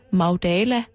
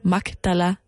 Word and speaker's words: Magdala: Øh, og man siger, at hun hedder Maria Magdalene, fordi hun formentlig kommer fra den lille by, der hedder Magdala, eller Magdala, alt efter hvordan man vil udtale det, ved Geneserets sø.